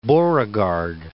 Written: Beauregard

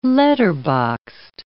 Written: letterboxed

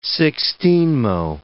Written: sixteenmo

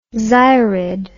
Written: xyrid

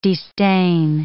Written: destain

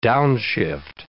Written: downshift

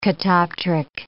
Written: catoptric

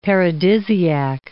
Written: paradisiac

